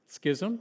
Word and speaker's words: schism: Now, I have a problem with this because how do you say the word schism? Schism? Oh, okay. Actually, you can say it three different ways. My preferred way has always been schism. You can say it that way, or schism, or schism.